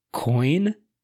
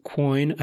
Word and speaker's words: quoin: and one of them is coin. Also, sometimes pronounced quoin, apparently, according to the Oxford Dictionary.